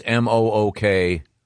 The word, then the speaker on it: mukbang: It's actually pronounced muk. Yeah. – Is it yeah. – muk? – Mukbang. – Yep. No, I thought it was mukbang. – The pronouncer here is mook. – Oh, okay. I've always heard muk. The people who do it, I've heard say muk.